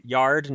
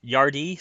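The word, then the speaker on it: Yarde: And by the way, everyone on the planet seems to be pronouncing it Yard, not Yardy, so no offense, Kieran, but I'm calling him Yard.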